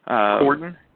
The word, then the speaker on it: Corden: Is it Corday? – Corden? Yes. – Corden, yeah. And I was going to bring him up before we get over to Jimmy Fallon.